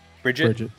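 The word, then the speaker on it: Brijite: Brigitte West, is that how you pronounce her name? It's B-R-I-J-I-T-E. – Bridget. Yeah.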